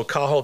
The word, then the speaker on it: Cathal: Also, Cathal Quinn of Ring of Loch Derg.